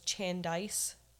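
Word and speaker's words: Chandise: Her name was Chandice.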